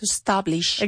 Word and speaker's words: establish: Where there is an E, we have to pronounce it. While we don't say I'm a Spanish, porque no se escribe E-S-P-A-N-I-C-H, you write it S-P-A-N-I-S-H, Spanish, there we don't have the E. But if the word is establish, con la E delante de la S, we do have to say it.